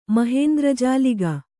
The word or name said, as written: ಮಹೇಂದ್ರಜಾಲಿಗ